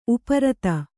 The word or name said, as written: ಉಪ್ಪಾರಿತ